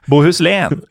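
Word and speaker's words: Bohuslän: Nei, Sverige var ikke fornøyd, Men Båhuslen er fortsatt en del av Sverige den dag i dag, og det uttales Bø... Båhuslen. Nei, det gjør det ikke. – Det uttales Båhuslen.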